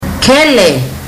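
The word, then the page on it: kele: Fijian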